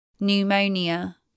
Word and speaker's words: pneumonia: PN spelling from Greek is silent P. Pneumonia, pneumatic.